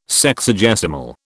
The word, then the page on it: Sexagesimal: British English